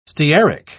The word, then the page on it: stearic: British English